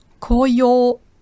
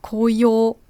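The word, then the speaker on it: Koyo (こうよう): Da gibt es zwei Aussprachen. Die japanische Lesung, das ist Momiji. Momiji. Oder die chinesische Lesung Koyo. Koyo.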